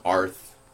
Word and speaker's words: Aurth: Yeah, like, you can tell that they're trying to say Earth, but they call it Arth. It's spelled A-U-R-T-H.